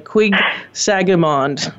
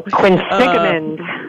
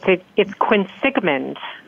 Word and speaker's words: Quinsigamond: It's something like Quig Sagamond. – Quinsigamond. – Okay. So how to complete the CSS profile, which is a very timely topic. So welcome. So how do you say the name of the community college you worked at? – It's Quinsigamond.